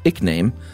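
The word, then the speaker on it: ekename: Looks like the term started as a Middle English word in the 1300s, E-K-E-name, pronounced ickname, meaning additional name.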